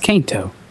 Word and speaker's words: Kanto: First thing we have is Pokemon's Kanto region in Minecraft. Redditor and Minecraft mediocre. – It's the Kanto region. Come on. – Kanto? Is it really Kanto? – Yeah, it's Kanto.